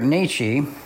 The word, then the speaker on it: Nietzsche: So Friedrich Nietzsche, or Nietzsche, I've heard it pronounced both ways, was a German philosopher.